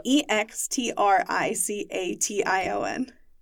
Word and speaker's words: extrication: Oh, how do you say that? Extrication, extra extraction what? Uh, what? E X T R I C A T I O N. Extrication. Extrication.